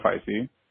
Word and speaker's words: Feisy: This is Dudeman JF, or Joshua Feisy. For those that have had trouble pronouncing my name in the past, just remember it rhymes with spicy. Spicy, spicy.